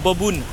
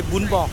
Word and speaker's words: bún bò: Les Français disent bobun, mais il faut bien prononcer bún bò.